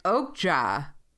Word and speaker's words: Okja: And Okja was maybe how you pronounce it. – Yeah.